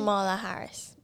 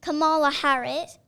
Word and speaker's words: Kamala Harris: Kamala Harris. – Kamala Harris.